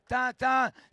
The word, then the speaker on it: Tintin: T-I-N sans haine, on le prononce sans haine, parce qu'il n'a pas de haine, Tintin, sinon quand il a faim.